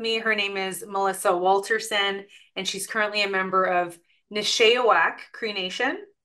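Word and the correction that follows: Nisichawayasihk: Did I say that right? – Nisichawasi Cree Nation. – It's way off. Nisichawasi Cree Nation.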